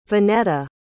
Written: vonetta

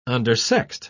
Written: undersexed

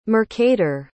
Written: mercator

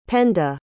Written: penda

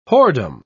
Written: whoredom

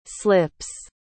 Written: slips

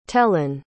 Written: tellan

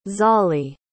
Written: zollie

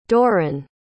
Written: Doron

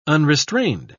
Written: unrestrained

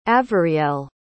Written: avarielle

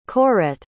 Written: Corette

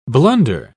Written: blunder

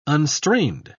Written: unstrained

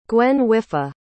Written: gwenhwyfach

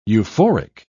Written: euphoric

